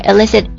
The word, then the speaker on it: illicit: 好，这个地方我要提一下，这个地方的 illicit 跟我们今天要分享的这个 illicit 一开头的这个 I 开头的和 E 开头他们的读音是完全一样的，但是呢这个 I 开头的 illicit 它是表示违法的不正当的，大家稍微留意一下。